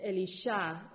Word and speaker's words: Elisha: How do you say Elisha? – Elisha. – Elisha.